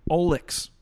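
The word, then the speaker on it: Olexik: Olix.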